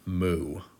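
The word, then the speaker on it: moo (無): Um, this is a character that I, am sure I'm going to pronounce it wrong, but it's it's um, translated transliterated into English as mu moo, like what a cow says, I guess.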